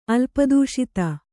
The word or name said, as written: ಅಲ್ಪದೂಷಿತ